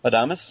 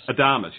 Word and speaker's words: Adames: Adames. – Adames, yes, Willie Adames, who also goes to Tampa Bay, and Nick Franklin goes from Seattle to Tampa Bay as well.